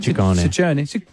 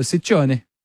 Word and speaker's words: Ciccone: Well, in Italian, it works the other way. So the double C's are hard C's and the singular C's are soft C's. – So Ciccione.